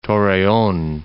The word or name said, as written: Torreon